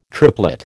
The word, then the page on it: triplet: American English